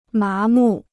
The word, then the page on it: 麻木: má mù